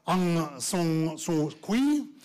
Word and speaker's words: Aung San Suu Kyi: But anyway, she is the extraordinary, and I must pronounce the name right, Aung San Suu Kyi. That is the correct pronunciation.